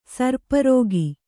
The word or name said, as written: ಸರ್ಪರೋಗಿ